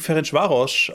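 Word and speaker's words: Ferencváros: Und Leverkusen spielt gegen Ferencvaros aus Ungarn, glaube ich.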